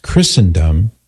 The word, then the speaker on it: Christendom: You've emphasized correctly, I know, that Christendom College is pronounced Christendom, not Christendom.